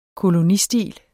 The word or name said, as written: kolonistil